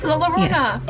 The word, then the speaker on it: La Llorona: La Llorona? – La Llorona!